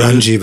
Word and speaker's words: Runge: Eben der Chris Runge oder Runge schreibt er sich am Ende. – Ich glaube, Runge wird es ausgesprochen. – Runge, also ja. Er schreibt sich Runge, ne? – Ja. – N-G-E.